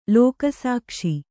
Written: ಲೋಕಸಾಕ್ಷಿ

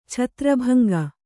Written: ಛತ್ರಭಂಗ

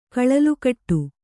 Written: ಕಳಲುಕಟ್ಟು

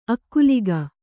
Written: ಅಗಲಿಕೆ

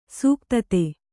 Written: ಸೂಕ್ತತೆ

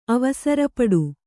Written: ಅವಸರಪಡು

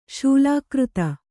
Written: ಶೂಲಾಕೃತ

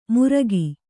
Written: ಮುರ್ಗಿ

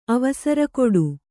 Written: ಅವಸರಕೊಡು

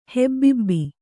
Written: ಹೆಬ್ಬುಬ್ಬು

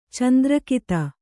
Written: ಚಂದ್ರಕಿತ

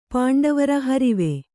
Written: ಹರಿವೆ

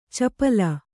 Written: ಚಪಲೆ

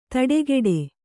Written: ತಡೆಗೆಡೆ

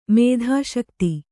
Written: ಮೇಧಾಶಕ್ತಿ

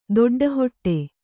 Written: ದೊಡ್ಡಹೊಟ್ಟೆ

